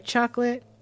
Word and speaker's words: Chocolat: And finally, Sydney, because no one wants to play with him, watches Chocolate.